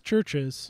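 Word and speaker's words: Chvrches: It's pronounced churches. – Okay. – So. – The go. – V is silent.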